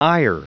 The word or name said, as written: ire